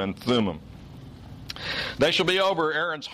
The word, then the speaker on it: Thummim: That's how I've usually heard these words pronounced in English. Hebrew has a tendency to want to stress the final syllable. So in Hebrew, this would really be the Urim and the Thummim.